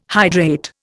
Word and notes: hydrate: Indian

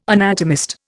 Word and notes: anatomist: Indian

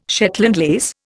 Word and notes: Shetland lace: Indian

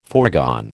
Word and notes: foregone: American English

